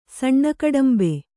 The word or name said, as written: ಸಣ್ಣಕಡಂಬೆ